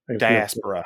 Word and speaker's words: diaspora: And thus created the initial dysphoria? – Diaspora. – Diaspora. I can never say that correctly.